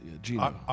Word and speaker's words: Gino: Help me with this UConn coach, Gene. – Yeah, Gino. – Ariema? – R.E.M.? Yeah, R.E.M.